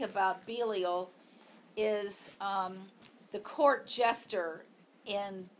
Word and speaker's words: Belial: B-E-L-I-A-L, or Belial, or Belial. And in the Bible, they put the emphasis on the first thing, but...